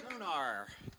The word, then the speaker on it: Sonr: Sonr? Sonr.